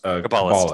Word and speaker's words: Kabbalist: Oh, and I believe it's pronounced Kabbalist.